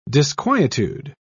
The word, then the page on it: disquietude: British English